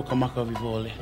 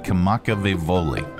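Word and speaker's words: Kamakavi-Viole (Kamakawiwoʻole): The ukulele version of the song Somewhere Over the Rainbow by Israel Kamakavi-Viole. How do you pronounce his name? Let's get it direct from him. – My name is Israel Kamakavi-Viole. – Okay, so we know that. Kamakavi-Viole.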